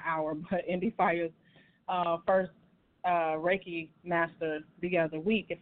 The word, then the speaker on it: Reiki: Is it Reiki Master? Am I saying that right? – You got it. That's it.